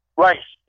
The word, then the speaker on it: Reis: Now, the young man's name, and correct me if I'm saying it wrong, is Reese? – Rice. It's German. It's a German name for Rice. My great-grandmother, maternal grandmother's maiden name was Rice, R-E-I-S.